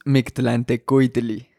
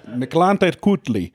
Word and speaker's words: mictlantecuhtli: Give us the correct pronunciation of this rattlesnake's name. – Crotalus mictlantecuhtli. – Yeah. – Miklantekutli. Formerly Crotalus simus, but now Miklantekutli.